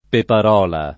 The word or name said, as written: peparola